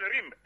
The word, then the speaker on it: Rim: Je m'appelle Rim, R-I-M. – Rim, oui. – R-I-M, prononcez comme vous voulez, R-I-M.